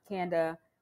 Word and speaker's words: Kanda: Wondering though, what you said before founding Kanda Chocolate. Am I saying it correctly? – Mm-hmm, but I welcome everyone that I'm not a stickler. So it's Kanda Chocolates, but I Kanda.